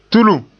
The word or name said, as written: tulum